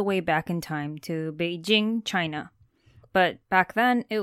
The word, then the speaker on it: Beijing: Beijing, meaning northern capital, is how it's pronounced in Mandarin, but Peking was the way it was pronounced and spelled by foreigners back in the olden days.